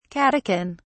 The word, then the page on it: catechin: British English